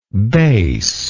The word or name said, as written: baseness